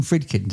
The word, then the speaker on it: Friedkin: You did some work with, is it William Friedkin? – Did I yes.